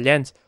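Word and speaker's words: Len: Bija abi jaunajā garjā piesaistītie Lens, kurš... – Tad, cik es nezināju, ka latviski Lens izrunā Ļens. Nu, tādi esmu... – Jā, Oleksijs Ļens izrādās, apparently.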